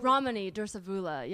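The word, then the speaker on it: Ramani Durvasula: Dr. Ramathi. Wait, how did I say that? She does all the narcissist videos on YouTube. – Oh. – Ramani Dursavula. Yeah.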